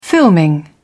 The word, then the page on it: filming: British English